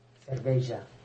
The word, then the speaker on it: cerveja: No, questo è portoghese. – Ah, sì? – Cerveja.